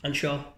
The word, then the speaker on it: Anseo: Now, I'm going to pronounce this wrong, but is it the Anshaw? – Anshaw. – Anshaw. Okay. – That's pretty good.